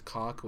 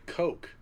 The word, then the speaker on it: Koch: It's KOCH. The punter for the Ravens is Sam Cook, and it's spelled the same way. I think it's a way to get around them pronouncing it the way it's spelled, which is Cock, which no one wants to be known as Cock Media.